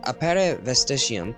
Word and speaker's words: Appare Vestigium: Appare Vestigium.